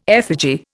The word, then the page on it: effigy: Indian